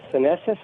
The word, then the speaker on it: senescent: So the reason I'm asking about the senescent cells, is that correct? – Yeah.